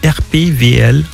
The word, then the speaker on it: RPWL: Alors, je viens de prendre RPWL, ce qui se prononce RPVL.